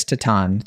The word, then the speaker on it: Titane: It is a little French film called Titane. Am I pronouncing that right, or is it Titane? – I think it's Titane. I think you said it right.